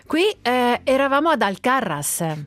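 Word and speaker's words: Alcarràs: Ho sbagliato l'accento. – No, fa niente. – No, è gravissimo. Alcarras, ce l'ho anche scritto davanti.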